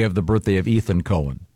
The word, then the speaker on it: Cohen: No, not when it's spelled C-O-H-E-N. – It's definitely Cohen.